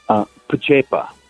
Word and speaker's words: Pacepa: Am I saying that right, Pacepa, or is it Pacepa? – Pacepa. – Pacepa, okay.